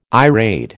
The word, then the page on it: irade: American English